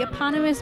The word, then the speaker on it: eponymous: Um, the success of the strip, which features the oh crap, I always say this word eponymous. – Mm-hmm. – I always say that wrong. I'm glad I said it right for actually once features the eponymous blonde and her sandwich-loving husband, wow, led to the long run running of Blondie film series 1938 wow.